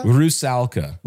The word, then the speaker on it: Rusalka: So how do you pronounce it? Rusalka? – Rusalka. – Rusalka. – R-U-S-A-L-K-A.